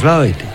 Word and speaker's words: Lloyd: Lloyd, que no, que es Lloyd. Entonces, hablando con la amiguita de Carlos Olazar, nos ha dicho que se dice así.